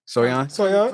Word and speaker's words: Soyeon: And Soyeon. Is that how you pronounce her name? – Soyeon.